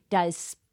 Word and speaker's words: Diasporican: So begin by telling us, what is a diaspora, a diasporic? I said it the wrong syllable again. Diasporican.